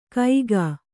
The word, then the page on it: ಕೈಗಾ: kaigā